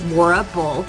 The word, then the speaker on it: Laura Bull: So yeah, it's like, okay, it's Bull, like the animal.